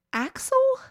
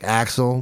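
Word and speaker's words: Asle: You know, I listened to a couple podcasts about this story, and I believe they all pronounced it Axel.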